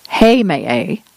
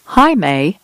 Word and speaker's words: Heimaey: There are at least three pronunciations of this island: Heimaey, Heimaey, and Heimaey. The freelance writer Jenny Crumrein was impressed by the people's devotion.